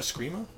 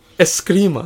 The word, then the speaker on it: Escrima: No, it's like Scream with an A. – There's an E at the beginning. – Is this a spelling podcast? Well, and a pronunciation? Oh no, it's I'm sorry. It is, I'm always fucking wrong. It's Escrima.